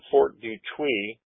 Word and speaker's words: Detroit: We currently pronounce it Detroit. But it's still spelled the same way. It's Fort Dutuy. There is a, that's the way the French pronounce it, you know.